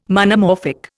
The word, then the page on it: Monomorphic: Indian